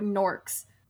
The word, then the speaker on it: Newark: Here's the thing, though, with norks. So, we live in New Jersey, and there's this huge thing where the town Newark is supposed to be pronounced Nork.